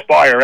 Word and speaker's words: Despire: Of course, you're from the band Despire. Am I saying that correctly? – Yes, you are. – So, Despire out of Long Island, New York.